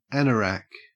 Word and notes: anorak: Australian English

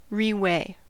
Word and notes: reweigh: American English

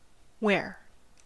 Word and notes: ware: American English

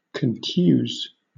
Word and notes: contuse: British English

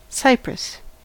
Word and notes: cypress: American English

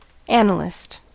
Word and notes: analyst: American English

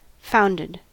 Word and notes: founded: American English

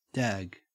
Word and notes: dag: Australian English

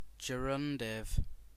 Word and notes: gerundive: British English

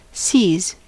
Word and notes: sees: American English

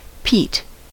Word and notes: peat: American English

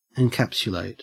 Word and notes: encapsulate: Australian English

